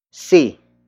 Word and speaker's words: si: That will be introduced by see, see, see. See is spelled as S-I.